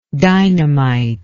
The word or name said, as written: dynamitically